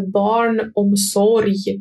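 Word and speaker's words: barnomsorg: и вообще официальное название это барн омсорьи. Барн омсорьи, красивое слово по-шведски, оно пишется не совсем так, как произносится, оно пишется барн омсорг. Барн омсорг читается барн омсорьи, название вашего муниципалитета и это слово страшное пишете.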